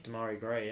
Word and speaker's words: Demarai Gray: Demarai Gray. Is that how you say his name? – Demarai Gray, yeah.